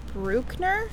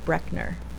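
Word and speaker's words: Brueckner: I would probably see Bruckner. Bruckner. – Germans pronounce things really Bruckner. – Fucking weird. – Yeah, that's true. – It's always like Brooke. I guess it would be Bruckner.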